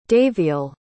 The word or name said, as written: daviel